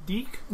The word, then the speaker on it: DIC: and that was Dick Entertainment, D-I-C. – Oh, yeah. Dick. I loved... – I'm sure it's pronounced Deek.